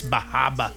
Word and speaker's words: Bar Harbor: Up there, they pronounce it Bahaba. – They'll correct you, too. It's Bahaba.